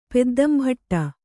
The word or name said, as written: ಪೆದ್ದಂಭಟ್ಟ